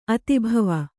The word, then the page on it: ಅತಿಭವ: Kannada